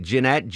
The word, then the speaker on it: Jeanette: It's pronounced Jeanette, but I'm fine, thank you. – Oh, so how is that? Is that with two T's and an E at the end? How does that work? – Yes, it's Jeanette. – Jeanette, but yes. I pronounce it Jeanette. – Oh, that's acceptable, just as long as you don't call me Janet.